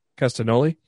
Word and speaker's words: Castagnoli: How do you say that last name? Castagnoli? – Castagnoli. – Yeah. Yep.